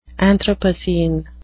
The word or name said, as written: anthropocene